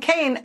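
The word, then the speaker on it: Kean: Looks like Keene, but it's pronounced Kaine.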